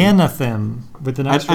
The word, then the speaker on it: Anathem: Yeah, well, it's definitely not anthem. No, because that's missing an A.